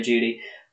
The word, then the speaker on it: Jeudy: Jerry Giudy. It's Jerry. – Is that how you pronounce his name? – No, it's Jerry Giudy, the wide receiver out of Alabama. I've got to say, I think Jerry Jeudy is the best wide receiver in this draft class.